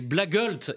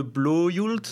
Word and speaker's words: blågult: Les Blågult, est-ce que je le dis bien? Les bleus et jaunes. – Je crois qu'on s'approche plus de Blåjult, quelque chose comme ça.